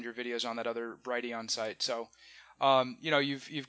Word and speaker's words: Brighteon: I know you also have, is it Brighteon? Is that how you pronounce? – Yeah, the Brighteon channel, yeah.